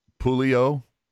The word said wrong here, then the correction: Pouliot: I'm, i know I'm not pronouncing that right. Derek Pouliot. Oh, Pouliot. Okay, see, I've heard the the ref the um, announcer thing that I have says Pulio.